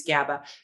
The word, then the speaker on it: GABA: G-A-B-A. – G-A-B-A. GABA. – For stress. It's great. – And just for kids or for adults as well? – Oh, either. Kids and adults can both use GABA.